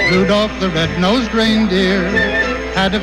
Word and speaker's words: Rudolph: Rudolph. – Do you say Salmon too? You don't pronounce the L in Rudolph.